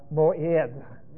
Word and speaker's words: moed: It is the word moed. Or moed. – I want to share that word with you because it's one word. – There's an ayin, which is unpronounced. – Or moed. – M-O, and this is that unpronounced ayin. E-D-H.